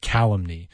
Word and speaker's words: calumny: C-A-L-U-M-N-I-A-T-E. Calumniate. Or the noun is calumny. I always hate when a word has a different pronunciation in its noun than it does its verb form. You know, like the stress is different. Like calumny is on that first syllable, whereas calumniate is on that maybe second or third.